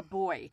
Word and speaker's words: boy: Diminished to her cock a boy. Now, boy. – Boy, like a buoy, like a, you know, some people pronounce it buoy, but boy.